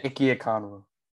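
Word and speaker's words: Ekwonu: Ikem Kwanu.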